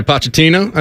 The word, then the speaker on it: Pochettino: Going with the men's coach, Mauricio Pochettino. Am I saying that right? Pochettino. – Yeah, Pochettino.